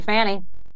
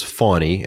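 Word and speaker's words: Fani: So thank you, Fannie. – First off, her name is Fawny, and they intentionally mispronounce her name over and over again.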